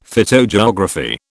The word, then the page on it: phytogeography: British English